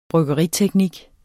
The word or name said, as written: bryggeriteknik